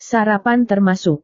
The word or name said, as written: sarapan termasuk